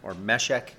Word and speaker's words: Meshech: Woe is me that I dwell in Meshech is probably a better pronunciation, that I dwell amongst the tents of Kedar.